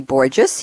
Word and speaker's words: Borges: In just a moment, I'll be welcoming back Bernie Borges. He always tells me it rhymes with gorgeous.